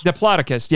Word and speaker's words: Diplodocus: Marsh sent an agent to make arrangements for what included the first specimens of the, I hope I say this right, Diplodocus. – Yeah, there you go.